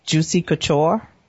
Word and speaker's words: Juicy Couture: When you are buying Juicy Couture, am I saying that right? – Yes.